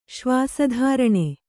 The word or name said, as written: ಶ್ವಾಸಧಾರಣೆ